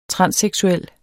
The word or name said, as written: transseksuel